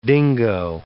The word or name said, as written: dingo